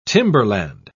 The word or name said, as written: timberland